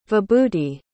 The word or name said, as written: vibhuti